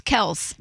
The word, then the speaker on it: Kelce: He said that it's one syllable. It's Kelce, not.